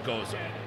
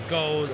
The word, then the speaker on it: Gose: But really the idea was people often mispronounce the style Goza. – Right. – It's G-O-S-E. People say Goes, Gose. Mm-hmm. You know, they often confuse it with a different style, which is a Goose.